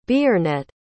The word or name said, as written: Beornet